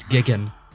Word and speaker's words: Jigen: You also missed out on the dub mispronouncing Jigen's name. It's Gigan.